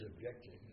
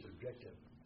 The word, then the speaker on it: objective: Not His objective, but His objective. Did I say it right? – Yes, you corrected it.